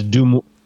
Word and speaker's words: Des Moines: It's French. You don't pronounce the S's. – Just like Illinois. So It's Des Moines.